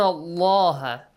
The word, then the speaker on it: الله: تحبون الله. The letter lam in the word Allah. – It's pronounced heavily. – Heavily.